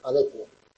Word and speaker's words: adecuo: Algunos serían como adecuar, que viene de ad aequo, para etimología, entonces es adecuo. Pero otros se pronuncian como averiguar, averiguo, no, es como adecuar, no, como actuar, actúo, en lugar de adecuo, ¿vale?